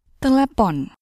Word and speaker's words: telepon: Telepon.